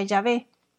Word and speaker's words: Yahvé: Aquí se refiere a Yahvé o Yahweh, Y-H-W-H. Eh, y en español se pronuncia Yahvé.